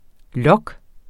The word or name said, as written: log